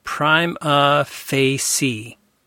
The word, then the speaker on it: prima facie: It says here, note to editor, suggested title pronunciation, prima facie. P-R-I-M-E-A-H. Fay, F-A-Y-S-E-E.